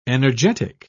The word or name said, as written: energetic